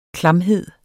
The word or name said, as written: klamhed